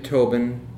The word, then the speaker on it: Tobin: David Tobin, T-O-B-I-N.